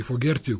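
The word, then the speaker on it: Fogerty: 22.38, pop-rock portret danas je posvećen Johnu Fogertiju, ili Fogertiju, kako u stvari se izgovara njegovo prezime, i sutra mu je 71.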